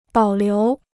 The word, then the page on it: 保留: bǎo liú